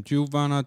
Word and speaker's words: Juwana: So it kind of sounds like Joanna, but Kind of. Yeah. – But it's actually really said the way it's spelled, J-U-W-A-N-A. Uh-huh. So it's Juwana.